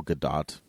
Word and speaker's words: Gadot: It's Gal Gadot. That's how you actually say her name.